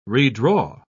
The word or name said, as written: redraw